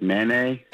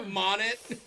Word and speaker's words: Monet: That's how you pronounce it? I thought it was like Manet. – Monet.